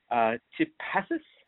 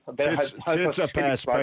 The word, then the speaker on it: Tsitsipas: And I think the main attraction, we've got the Greek god Stefano Tsitsipas. – Tsitsipas. And you played tennis and you couldn't get his name right.